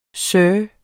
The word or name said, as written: SIRS